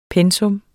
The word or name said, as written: pensum